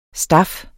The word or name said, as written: staf